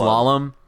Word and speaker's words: slalom: How do you pronounce that word? Salem? Slalom? Slalom? – Slalom? – Slalom? – Slalom. – Yeah, the snow is...